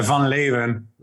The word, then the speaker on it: Van Leeuwen: So, Matt, I always can't pronounce your last name, Matt. Can you pronounce it for the audience for me? – It's the Van Leeuwen. – Van Leeuwen, right? – So, Van Leeuwen, yeah.